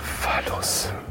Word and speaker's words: Phallus: Patulus? Ist das richtig ausgesprochen? – Phallus.